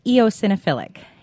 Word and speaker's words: eosinophilic: Well, it's pronounced eosinophilic,